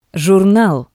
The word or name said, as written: jurnal